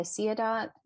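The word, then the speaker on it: Siadat: On Clubhouse, it's just my name, Cynthia Ciadot, and that's CynthiaSiadat, and pronounced like Ciadot on a page.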